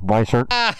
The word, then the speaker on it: Weissert: Reliever Greg, pronunciation please, Weissert. – We're going to go with Weissert.